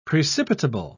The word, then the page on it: precipitable: British English